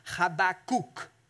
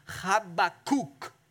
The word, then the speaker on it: Habakkuk: We always say Habakkuk in church. You pronounce it in Hebrew, Habakkuk. That's how you pronounce it. Try it with me. Habakkuk.